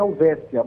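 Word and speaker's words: Helvetia: A pronúncia é Helvécia, mas escreve com T.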